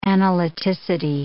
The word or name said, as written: analyticity